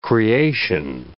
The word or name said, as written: creation